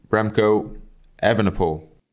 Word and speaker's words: Remco Evenepoel: So, welcome to the pod, welcome to the GTCC, Remco, Evenepoel.